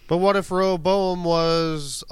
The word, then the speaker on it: Rehoboam: we're seeing like Avatar of Roa Boam. Ro- is that right? – Did I actually say it right? Rehoboam. – Yeah. Rehoboam uh, mm-hmm.